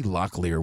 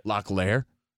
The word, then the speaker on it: Locklear: You say Locklear weird. – How do you say it? – Locklear. – It's Locklear. – I say Heather Locklear. – You're putting, like, way too much emphasis on the layer. – You put the emphasis on the leer.